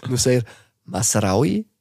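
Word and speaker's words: Noussair Mazraoui: Nusser Masraoui. – Das wird immer besser. Masraoui wird immer besser. Perfekt ausgesprochen.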